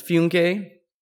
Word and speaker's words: Funke: It's spelled like funky, but it's Funke.